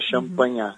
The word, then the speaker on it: Champagnat: Hospital Marcelino escreve Champagnat, né? Se pronuncia Champagnat.